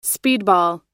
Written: speedball

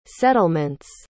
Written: settlements

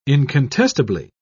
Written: incontestably

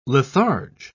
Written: litharge

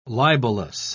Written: libelous

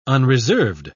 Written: unreserved

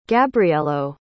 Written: gabrielo

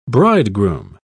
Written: bridegroom

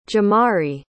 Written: Jemarie

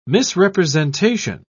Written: misrepresentation

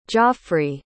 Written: joffrey

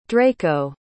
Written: draco

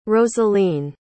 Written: rosaleen

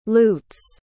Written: loots

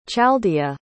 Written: chaldea

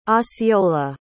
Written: osceola

